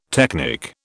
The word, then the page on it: technic: British English